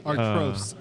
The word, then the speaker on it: Artois: Artois.